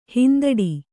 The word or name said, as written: ಹಿಂದಡಿ